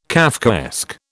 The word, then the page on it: Kafkaesque: British English